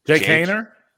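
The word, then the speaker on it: Haener: I thought Jake Hefner, is that how you say his name? Jake Haner? – Him, yeah. Jake Haner? Yeah.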